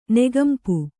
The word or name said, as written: ನೆಗಂಪು